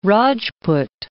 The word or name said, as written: Rajput